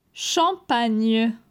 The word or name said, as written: Champagne